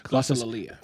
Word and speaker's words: glossolalia: But then there's the second, which a lot of the glossia, what is it? – Glossolalia. – Thank you, glossolalia. I knew there was another syllable on there.